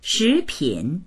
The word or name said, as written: shi2pin3